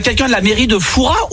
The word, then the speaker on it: Fouras: Eh bien écoutez, on dit Foura. Ne prononce pas le S.